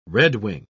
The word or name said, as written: redwing